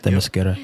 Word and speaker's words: Themyscira: From Themyscira. Did I say that right? – Themyscira, yes.